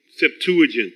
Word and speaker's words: Septuagint: The footnote for Proverbs 23:7 says the Aramaic, I still haven't figured out how to pronounce this word, Septuagint, and a few Hebrew, Septuagint, thank you. The Septuagint, now it rolls off, Septuagint.